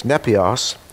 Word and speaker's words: nepios: There is a word, I'll spell it for you, it's nepios, N-E-P-I-O-S, N-E-P-I-O-S.